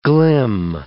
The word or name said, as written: glim